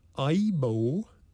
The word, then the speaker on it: Aibo: It's called the Sony Aibo, A-I-B-O.